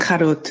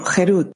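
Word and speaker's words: jerut (חרות): No pronuncien Harut, sino jerut libertad.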